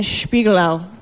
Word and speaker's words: Spiegelau: and Spiegelau.